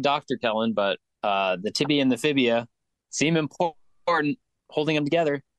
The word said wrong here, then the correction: fibula: Fibula. How dare you mispronounce a medical term?